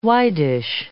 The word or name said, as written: widish